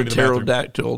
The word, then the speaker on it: pterodactyl: Yeah, why can't you hear a pterodactyl that's peeing? – Why? – Why can't you hear a pterodactyl? Oh, I know. Yeah. – Because the P is silent.